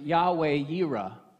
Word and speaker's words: Yahweh Yireh: In Hebrew, you would pronounce it Yahweh Yireh.